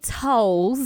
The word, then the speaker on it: Towles: So, my last name is Janae Towles. – Oh, okay. – Not Towels. – Okay. My bad. – It's T-O-W-L-E-S.